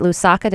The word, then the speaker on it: Lusaka: Is it Lusaka? Did I pronounce that right? – Yes, you did.